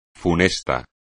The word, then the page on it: funesto: espagnol